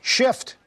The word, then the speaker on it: SH/FT: Shift.